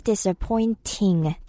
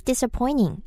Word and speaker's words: disappointing: That's disappointing. ting으로 발음해도 되고, that's disappointing. 이렇게 ning 사운드처럼 들려도 괜찮아요.